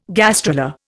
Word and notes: gastrula: Indian

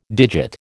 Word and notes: digit: American English

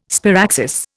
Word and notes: sparaxis: Indian

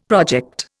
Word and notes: project: Indian

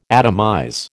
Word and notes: atomize: American English

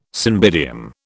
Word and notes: cymbidium: British English

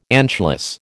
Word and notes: anschluss: American English

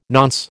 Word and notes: nonce: American English

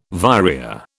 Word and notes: vireo: British English

